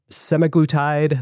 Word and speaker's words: semaglutide: Are we pronouncing semaglutide, liraglutide? How do you pronounce it at Novo Nordisk, Lotte? – I think you're doing pretty good.